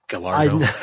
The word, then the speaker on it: Gallardo: Is that how you say it? Gallardo? – Yeah, Gallardo.